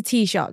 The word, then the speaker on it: Taoiseach: So for context, because I didn't know what a T-shock was, and it's spelt a really funny way because when you were texting me, I was like, what's a Taoiseach? But it's a T-shock, that's how you say it.